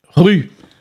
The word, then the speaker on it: ruz: Ceci nous amène directement, bien entendu, au mot rouge, rue. Donc, R-U-Z. Alors, j'ai dit que le mot rouge donc, s'écrit R-U-Z, mais dans la plus grande partie de la Bretagne, le Z, en fait, ne se prononce pas.